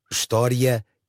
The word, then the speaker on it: história: A palavra história tem 3 sílabas. História. Porque num débito de fala normal, a sequência final da palavra é pronunciada como um ditongo crescente, ia.